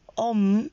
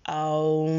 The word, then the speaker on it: Om: Por exemplo, o om, ou como é pronunciado, aum, vibra a 432 Hz, que é a mesma frequência vibracional encontrada em todas as coisas na natureza.